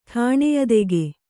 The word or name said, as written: ಠಾಣೆಯದೆಗೆ